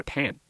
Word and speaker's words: Tan: 我也给大家科普了一下，就是马来西亚华人登记姓名的时候就方言发音，比如姓陈的福建人发音叫 Tan，登记官就写 Tan；姓陈的广东人发音叫 Chan，登记官就写 Chan。